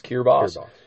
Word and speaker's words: Kiribati: there's you know a mixture of islands in that Kiribati uh, chain Kiribati is a uh, pr- pronunciation for that country hmm. Kiribati but it's like got T's and B's and stuff in it but yeah. – it's pronounced Kiribati.